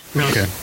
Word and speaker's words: Rilke: Did I say Rilke correctly? Or is it something, is it pronounced a different way? – Rilke. Mm-hmm. – Rilke? – Rilke.